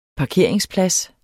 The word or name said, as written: parkeringsplads